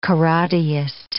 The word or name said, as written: karateist